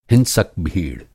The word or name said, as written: हिंसक